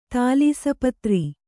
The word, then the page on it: ತಾಲೀಸಪತ್ರಿ: tālīsa patri